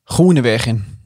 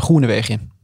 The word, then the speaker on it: Grånevegen: Han mm. sier vel Ronevegen. Ja. Uten også, tror du dropper siste n i navnet. Altså, Ronevegen, mm.